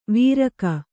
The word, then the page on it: ವೀರಕ: vīraka